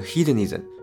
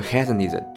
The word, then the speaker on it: hedonism: 第一种观点强调感受的好坏，我把它叫做正负感受论，对应英语中的 hedonism 或者 hedonism 这两种发音都可以。